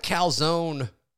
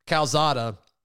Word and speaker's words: Calzada: But it sounds like Calzone – I got to start saying his name right. Calzada – First of all, it's amazing how widely renowned that is.